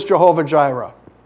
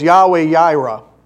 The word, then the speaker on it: Yahweh Jireh: What is Jehovah Jireh? The God who provides. The Lord will provide. The correct pronunciation for that, though, is Yahweh Jireh.